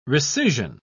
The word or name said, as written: recision